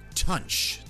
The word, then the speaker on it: Tunche: I think this is pronounced Tunch. T-U-N-C-H-E. Tunch.